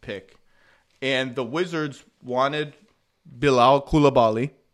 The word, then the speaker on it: Bilal Coulibaly: Do you want to try, Zach, or do you want me to try my debut pronunciation of Bilal Coulibaly? – I think you nailed it.